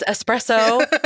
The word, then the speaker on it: espresso: Both of them said espresso. – And that bothered me. So I'm going to say it correctly. It's espresso. That's what the footnotes. That's what the closed captioning said. That's how you're supposed to say it.